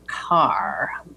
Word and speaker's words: Kerr: and Deborah, excuse me, uh, someone has just handed me a note that says we're pronouncing her name wrong. Deborah Carr. K E R R is pronounced Carr.